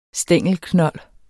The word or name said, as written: stængelknold